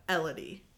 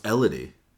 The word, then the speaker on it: Elodie: I want to say Elodie. – Yeah. – But it's Elodie. – It's like Melody, but Elodie. – Oh, it's Elodie. Yeah, I've been saying it wrong this whole time.